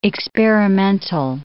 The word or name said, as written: experimental